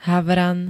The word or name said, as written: HAVRAN